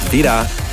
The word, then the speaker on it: Vyda: They're called Vida. It's spelled V-Y-D-A, all in capitals.